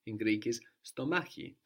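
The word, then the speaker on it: stomachi (στομάχι): So this X letter, this K sound, is pronounced in two slightly different ways. It's kind of soft when it has an E or an I after it, stomachi.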